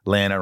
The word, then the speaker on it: Lana: Hold on to Smallville, Estevan G, Garrett W, Bob K, Kimberly L, Tom N, Jason W, Osama A, Glenda P, Lana rhymes with banana W,